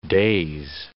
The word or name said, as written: days